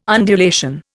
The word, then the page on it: undulation: Indian